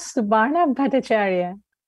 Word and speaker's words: Bhattacharyya: That is Subarna Bhattacharyya.